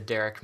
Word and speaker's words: Derek: I'm at the Derek Mast. It's spelled with D-E-R-E-K, the only proper way to spell the name Derek.